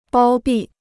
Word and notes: 包庇: bāo bì

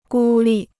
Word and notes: 孤立: gū lì

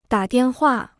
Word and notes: 打电话: dǎ diàn huà

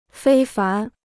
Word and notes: 非凡: fēi fán

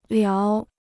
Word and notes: 聊: liáo

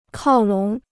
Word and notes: 靠拢: kào lǒng

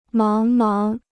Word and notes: 茫茫: máng máng

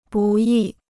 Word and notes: 不易: bù yì

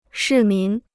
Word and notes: 市民: shì mín

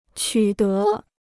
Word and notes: 取得: qǔ dé

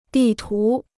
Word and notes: 地图: dì tú

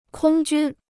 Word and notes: 空军: kōng jūn

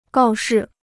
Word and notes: 告示: gào shi

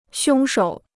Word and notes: 凶手: xiōng shǒu

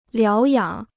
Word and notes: 疗养: liáo yǎng

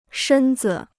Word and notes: 身子: shēn zi

